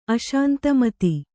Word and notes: ಅಶಾಂತಮತಿ: Kannada

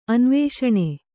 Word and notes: ಅನ್ವೇಷಣ: anvēṣaṇa; Kannada

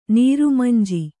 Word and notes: ನೀರುಮಂಜಿ: nīru manji

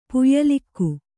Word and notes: ಪುಯ್ಲಿಕ್ಕು: kannada

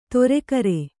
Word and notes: ತೊರೆಗರೆ: toregare

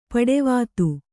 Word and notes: ಪಡಿವಾತು: paḍivātu

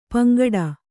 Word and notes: ಪಿಂಗಡೆ: piŋgaḍe

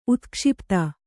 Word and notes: ಉತ್ಕ್ಷಿಪ್ತ: utkṣipta

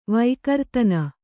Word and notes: ವೈಕರ್ತನ: vaikartana

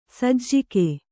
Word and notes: ಸಜ್ಜಕ: sajjaka

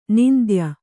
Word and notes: ನಿಂದ್ಯ: nindya

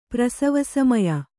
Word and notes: ಪ್ರಸವಸಮಯ: Kannada

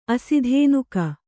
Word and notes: ಆಸ್ಥಾನಿಕ: āsthānika

